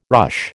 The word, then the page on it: ruche: American English